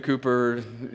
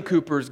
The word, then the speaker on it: Cowper: William Cooper, you're reading it right now. Okay. Look up William Cooper's God Works in Mysterious Ways. It's spelled Cowper, C-O-W-P-E-R.